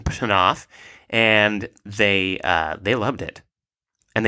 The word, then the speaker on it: Knopf: It's German, so the original pronunciation would be with a short, hard K. – Knopf.